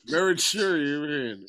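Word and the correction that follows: Maruchan: It's Marichan. – Five syllables.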